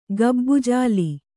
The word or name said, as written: ಗಬ್ಬುಜಾಲಿ